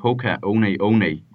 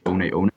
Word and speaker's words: Hoka One One: We see the counter to this, and that was the maximalist boom, which was the brand Hoka One One. You might know it as Hoka One One, but it's actually pronounced One One.